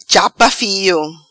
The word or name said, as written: Chapa Fio